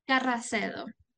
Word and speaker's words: Carracedo: So go to World Beyond War and put in Anila, A-N-N-I-E-L-A, Corridor. Say your last name. – Carracedo. – Oh, isn't that beautiful? C-A-R-R-A-C-E-D-O, A-K-A, Annie.